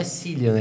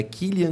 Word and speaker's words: Cillian: E aí me falaram que a pronúncia não é Cillian, é Killian Murphy.